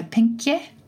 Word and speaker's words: pinkje: It's P-I-N-K-J-E. So pinkje, maybe? – Pinkje? – Yes, pinkje. Yeah. Very good.